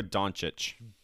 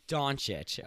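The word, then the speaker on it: Dončić: Luka Doncic. – Doncic.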